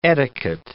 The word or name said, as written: etiquette